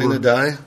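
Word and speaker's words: sine die: How's this pronounced? – Sign-y. – It's over. – Sign and die. It's over. Yeah. Done. Closed. – Yeah. Okay, you got it.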